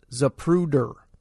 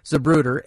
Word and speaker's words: Zapruder: Anticipating my next question, she said my friend couldn't spell the name, only pronounce it, Zapruder. I picked up the phone book and ran my finger down the Z's, and there it was, just as Patsy had pronounced it, Zapruder, Abraham.